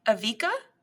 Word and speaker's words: Evika: And our main character here, Evika, I think is how you say mm-hmm. it. E-V-I-K-A, Evika. We're going to go with that.